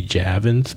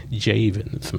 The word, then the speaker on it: Javins: Uh, for the longest, I've been saying Marie Javins, but uh, one of the first things that she said when she got up on the stage was that it was actually pronounced Javins, Marie Javins.